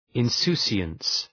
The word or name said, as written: insouciance